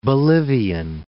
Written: Bolivian

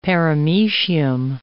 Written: paramecium

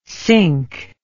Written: sync

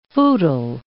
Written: footle

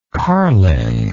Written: carling

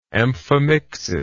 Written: amphimictically

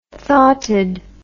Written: thoughted

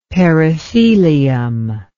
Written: perithelium

